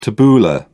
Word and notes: tabbouleh: British English

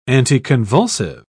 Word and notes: anticonvulsive: British English

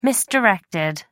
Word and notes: misdirected: American English

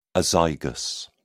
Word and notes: azygous: British English

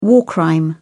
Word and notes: war crime: British English

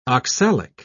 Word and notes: oxalic: British English

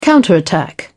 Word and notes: counterattack: British English

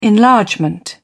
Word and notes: enlargement: British English

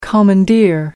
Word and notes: commandeer: American English

